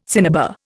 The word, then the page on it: cinnabar: Indian